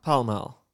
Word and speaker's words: Pall Mall: Because how it's spelled, it should be mall. – I mean, i- if you're questioning why English sounds, Why is it pal mall"? mal? What's pal?